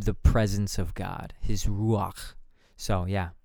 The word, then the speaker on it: ruach: Yeah, so we looked at the word, the Hebrew word of ruach and yes, you do have to get your throat into it a little bit to pronounce it properly, but ruach was the word that we had.